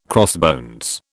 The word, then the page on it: crossbones: British English